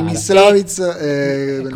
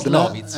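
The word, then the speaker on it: Myslovitz: Si chiamano Mislovitz o Mislovitz. Adesso l'accento sì, non so esattamente dove va. – Mislovitz. – Come si chiama? – Mislovitz Fixia Yes Mod. Mislovitz, ecco.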